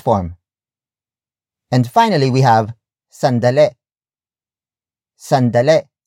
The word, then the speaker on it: sandali: Tagalog speakers tend to relax the E sound at the end, making it sound like E instead. So what should have been pronounced as sandali, sandali, sandali becomes sandale.